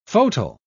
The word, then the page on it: fauteuil: British English